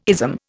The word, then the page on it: ism: Indian